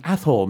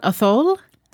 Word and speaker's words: Athol: There is a great town name in central Western Massachusetts, which is, it is spelled A-T-H-O-L. That's right. Athol, Massachusetts. Actually, I'm from Athol, Massachusetts. – Wow. Yeah. It's not Athol? – No, Athol.